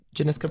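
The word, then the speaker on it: Janicza: Yeah, this is definitely, I feel like, so on the first day I went to, Jacqueline Coley did the conversation with, it's Janiska, right? Janiska Bravo? – Thank you. – I want to make sure I'm pronouncing it right, but she did like a one-on-one kind of intimate conversation with her that I went to, and we were all laughing.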